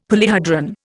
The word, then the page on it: polyhedron: Indian